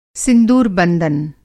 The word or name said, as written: सिंदूरबंदन